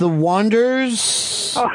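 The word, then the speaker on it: The Wonders: The Oneaters. – That's pronounced the Wanders.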